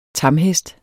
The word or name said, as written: tamhest